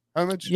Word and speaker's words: Homage: He actually showed up to camp in a very cool homage t-shirt. Is it homage? Homage? – Yeah, homage.